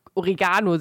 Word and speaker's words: Oregano: Ja, richtig, aber es, äh, man spricht es ja genauso aus wie Orega, Ori, Oregano. Siehst du, ich kann es nicht mal falsch aussprechen. Origami? Nee. Oregano. Doch, da macht man beides auf das A. – Ja. Aber da ist ein I und ein E, das ja. anders ist. Ja, aber die N Betonung ist gleich. Beides aufs A.